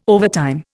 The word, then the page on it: overtime: Indian